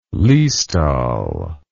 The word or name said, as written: liestal